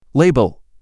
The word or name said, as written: Label